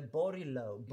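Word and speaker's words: Borglowe: Den andra låten som tävlar ikväll är Melina Borglund Borglove, kan man uttala det så? Eller Borglov? – Borglov.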